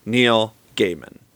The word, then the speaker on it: Neil Gaiman: And he himself pronounces his name Neil Gaiman.